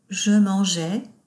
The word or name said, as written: Je mangeais